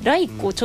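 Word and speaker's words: 雷粉: ということでね、はい、ライコをちょっと調べてみたんですけれども、うん、こちらの雷の粉と書いてライコで調べると、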